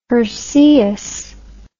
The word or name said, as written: herceius